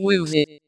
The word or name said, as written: footballer